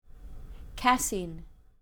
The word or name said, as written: casein